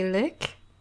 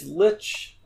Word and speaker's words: lich: You pronounce the the wizard number four. You say lick. – It should be what is it? It's lich it, it's not a word I use often enough to like have a natural pronunciation. It should be lich, right? – I have no idea.